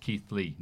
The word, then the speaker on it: Keighley: Pronounced Keith Lee, not Keighley.